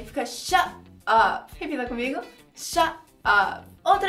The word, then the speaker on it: shut up: Primeira pronúncia fazendo o som do T, claramente, tipo shut up, shut up. Ou você pode transformar o T num R aí no som e fica shut up.